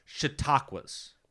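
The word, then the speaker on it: Chautauqua: There are these things out there called chautauquas.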